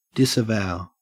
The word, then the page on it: disavow: Australian English